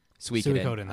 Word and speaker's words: Suikoden: He says Time Splitters and Sudoku. – I haven't thought about Sudoku in forever. – Suikoden? – Suikoden. – That's it. Sorry. – I knew I was saying it wrong. I was like, that doesn't sound right.